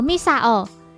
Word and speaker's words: Misao: 三三零的日语读作 Misao，也就是中村超的名字。